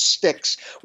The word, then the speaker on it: Styx: And the second one is called Styx, which is spelled S-T-Y-X, everybody, like the band and like the river Styx, which is really what the title refers to, the famous river in ancient Greek mythology that goes to the underworld.